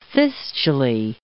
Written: fistulae

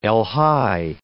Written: elhi

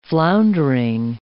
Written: floundering

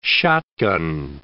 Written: shotgun

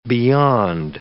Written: beyond

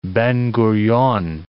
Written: Ben-Gurion

